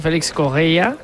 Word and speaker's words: Félix Correia: A pronunciarlo bien, Stassin, como nos han apuntado, y Félix Correia, como dice José, con muy buena pronunciación portuguesa.